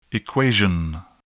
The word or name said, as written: equation